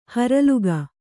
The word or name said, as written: ಹೊರಲಿಗ